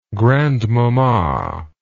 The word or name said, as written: grandmamma